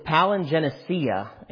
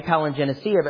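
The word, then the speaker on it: palingenesia (παλιγγενεσία): It literally is palingenesia, and it's spelled palin and genesis, this word regeneration. And it's pronounced that way, palingenesia, but literally spelled out palin and genesis.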